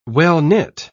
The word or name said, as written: well-knit